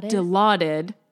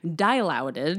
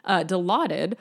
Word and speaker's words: Dilaudid: Like, I know how to say it, but when I look at the word, I'm like, dilouted. Like, no, I always want to say die. Yeah. Dilaudid. Mm-hmm.